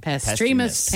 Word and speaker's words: Pastremus: So I just want to, one more time, how do I say it's Pastremus? – Pastremus paint. – Typing it out phonetically. – Pastremus. Right? – Pastremus. – Yeah. – Pastremus. – Yeah. Okay, cool.